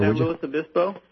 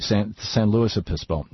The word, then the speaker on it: San Luis Obispo: San Luis Obispo? – Thank you, San Luis Obispo.